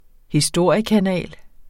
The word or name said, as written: historiekanal